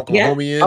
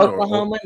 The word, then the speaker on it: Oklahoman: Is it Oklahoman or Oklahomian? – Yeah, Oklahoman.